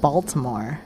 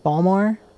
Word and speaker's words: Baltimore: That's not how you pronounce it. – It's not? – It's not Baltimore. – How do you pronounce it? – Balmore. – Balmore? Sorry, I don't have that accent.